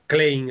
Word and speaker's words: Klein: Klein escrito, pero de origen alemán, por lo tanto habría que pronunciarlo a la alemana.